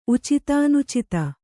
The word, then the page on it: ಉಚಿತಾನುಚಿತ: Kannada